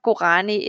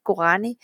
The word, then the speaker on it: Gourani: Sulaima Gourani is, and maybe if I spell it, it's like S-O-U-L-A-I-M-A. And my last name is G-O-U-R-A-N-I, Gourani.